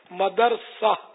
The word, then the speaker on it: مدرسہ: مدرسہ مدرسۃ المدینہ نہیں ہے, مدرسہ دال جو ہے نا ساکن ہے, مدرسۃ المدینہ.